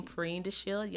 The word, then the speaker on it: Perrine DeShield: It's my name, Perrine DeShield. Yes, it's an odd name. It's spelled name. – P as in Paul, thank you. P as in Paul, E-R-R-I-N-E, D as in David, E-S-H-I-E-L-D.